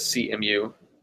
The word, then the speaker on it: Cemu: But I remember it SEMU and the first version of SEMU. I say SEMU, it could be CEMU. How do you say it? – Yeah, I've kind of seen it as CMU.